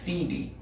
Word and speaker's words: Phoebe: Another spelling of the same name is P-H-O-E-B-E. And so when I looked up how to pronounce her name, it was pronounced Phoebe. So because the P and the H make an F sound. So it's Phoebe. So this sister that he is commending here, calling her Phoebe,